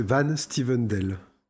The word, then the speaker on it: Van Styvendael: On continue euh, avec, euh, avec Cédric euh, Van Stuvendal. J'espère que je prononce correctement. – C'est plus simple, c'est Van Stevendel. Van Stevendel. Voilà.